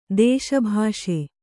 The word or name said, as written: ದೇಶಭಾಷೆ